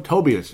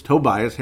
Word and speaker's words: Tobias: Tobias, as I'm going to say it correctly. I always call him Tobias. It's Tobias Harris. And I knew it's wrong. And I just could not correct myself, for cripe's sake.